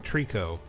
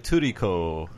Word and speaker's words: Trico: yet he does not pronounce the name Trico. – I guess he says uh, like Turico.